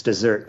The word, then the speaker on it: Desert: There's one called Mount Desert Island, And it's spelled like desert, but I think it's dessert. I think dessert was a person. That's a proper name.